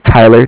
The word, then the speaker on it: Tyler: Yeah, my name is Tyler, T-Y-L-E-R. – Uh-huh. – And I'm calling from Bank of Cardiff. It's spelled C-A-R-D-I-F-F, as in Frank Frank.